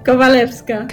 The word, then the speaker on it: Kowalewska: Kowalewska.